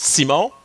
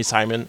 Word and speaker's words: Simon: I'm your host, Simon. By the way, saying it right now, this is how I say it in like French-Canadian. You can call me Simon.